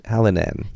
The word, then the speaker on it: Hallinan: Terence Hallahan. Sure. I, am not sure that's how to pronounce his name. – Hallen, Hallen, Hallinan.